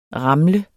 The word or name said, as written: ramle